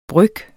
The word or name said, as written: bryg